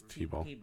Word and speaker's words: Thibault: So, if you're celebrating your birthday this week, you're celebrating with Chad Chapman, Christopher Worthington, Chris Thibault. I always say that wrong. It's Thibault. – Thibault. – Silent H.